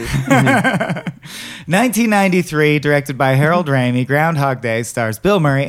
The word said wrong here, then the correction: Ramis: I need to know if you're being cute or if you think it's pronounced Ramey. – Oh, Ramis? – Yeah.